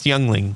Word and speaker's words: Yuengling: Yingling? – I think it's pronounced Youngling, but okay.